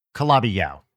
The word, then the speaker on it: Calabi-Yau: It's Calabri or something like that. – Calabi Yau. – Calabi Gao? – Yau. Calabi Yau.